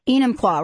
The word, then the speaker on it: Enumclaw: But I think it's in Umpqua. Did I say that right? – Enumclaw, right? – In Umpqua, whatever.